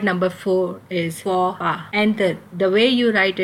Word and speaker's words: faux pas: F-A-U-X space P-A-S. So F-A-U-X is pronounced as faux and P-A-S is pronounced as pas. So faux pas.